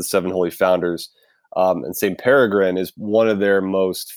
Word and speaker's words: Peregrine: Tell us a little bit about St. Peregrine, which I always want to pronounce Pergerine, but it's not. – Not at all. – It's Peregrine.